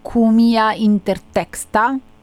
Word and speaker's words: Cumia intertexta: Allora, è corretto dire che questa lumaca vampiro, nome scientifico, aspetta, vediamo se lo pronuncio bene, è Cumia intertexta, giusto? – Perfetto.